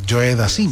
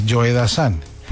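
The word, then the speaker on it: Joe Dassin: Y entonces presentó a Joe Dazan como si fuera Joe Dazin, como se escribe. Joe Dazin. Entonces yo le dije, no olvides de Anilla, que es Joe Dazan.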